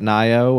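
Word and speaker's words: Nioh: We are getting a pretty, I don't know, high-profile title next week, but is it pronounced Nio? – Nier? – Nier? – No, I think it's talking about Nio.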